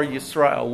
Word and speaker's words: Yisrael: Yisrael with a Y. That's the Hebrew way to say it.